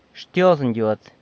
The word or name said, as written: Xtyozën yuad